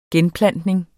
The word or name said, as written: genplantning